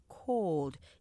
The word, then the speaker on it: cold: 콜드, 추운 할 때 이음절이 아니라 cold 이름절로 하셔야 되고요.